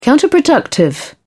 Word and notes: counterproductive: British English

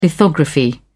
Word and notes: lithography: British English

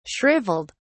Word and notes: shriveled: British English

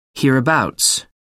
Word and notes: hereabouts: American English

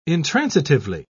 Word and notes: intransitively: British English

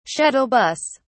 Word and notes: shuttle bus: British English

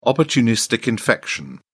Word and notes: opportunistic infection: British English

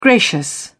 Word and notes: gracious: British English

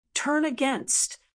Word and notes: turn against: American English

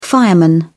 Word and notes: fireman: British English

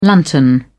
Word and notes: lantern: British English

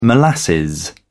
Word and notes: molasses: British English

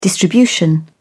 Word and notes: distribution: British English

